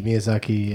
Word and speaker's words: Miyazaki: How do you say it? – Miyazaki, yeah.